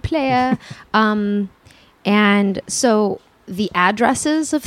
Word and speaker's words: playa: So number one, it's pronounced playa. – Okay. – Because it's like a dried up lake bed, so they call it the playa.